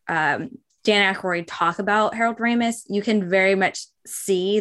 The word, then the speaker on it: Ramis: And that was be a nod and a love letter to Harold Ramis.